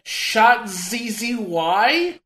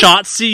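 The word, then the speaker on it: Shotzzy: Plus, you've got Canyon and Shot ZZY. – That's pronounced Shotzi, you uncultured fuck.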